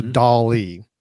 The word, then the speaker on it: DALL-E: So on that same OpenAI website, they also have a service that is called DALL-E, just like Salvador Dali, but it's spelled DALL-E or dot E. So DALL-E.